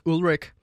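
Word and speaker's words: Ulrich: Lars Ulrik som det egentlig udtales.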